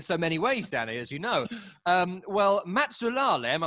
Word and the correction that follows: Matusalem: I'm sure I'm getting that wrong. – No, I've worked it out now. You're talking about Matusalem.